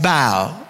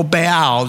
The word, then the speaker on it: Baal: They were specifically worshiping a god called Baal, or Baal, depending on how you want to pronounce it.